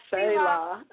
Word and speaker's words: Selah: Selah.